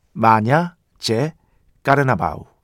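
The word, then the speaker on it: Manhã de Carnaval: 카니발의 아침, 마냐, 제, 까르나바우. 요 정도 발음 될 겁니다.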